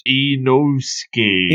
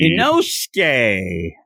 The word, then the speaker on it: Inosuke: Inosuke. – Inosuke.